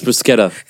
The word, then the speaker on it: bruschetta: And it's pronounced bruschetta. – Oh shit. With the C.